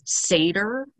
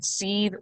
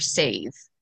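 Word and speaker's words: seiðr: So yeah, a lot of people I've seen pronounce it as Seder, but that's not the actual pronunciation. So it can be pronounced one of two ways. You can either say it seethe or save, but I like to pronounce it like seethe because it kind of reminds me of being angry.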